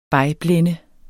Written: begblende

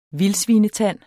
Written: vildsvinetand

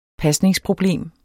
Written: pasningsproblem

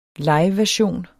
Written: liveversion

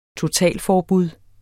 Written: totalforbud

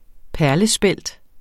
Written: perlespelt

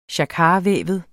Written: jacquardvævet

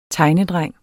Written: tegnedreng

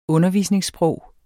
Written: undervisningssprog

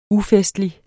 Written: UFESTLIG

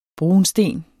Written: brunsten

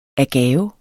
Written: agave

